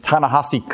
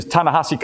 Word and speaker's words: Ta-Nehisi: His name is Ta-Nehisi Coates. You have to practice pronouncing his name. I always have to remember, how do you spell that and how does it sound? But his name is Ta-Nehisi Coates.